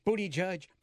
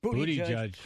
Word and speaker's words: Buttigieg: Or however you say his name. – Booty judge. – Booty judge.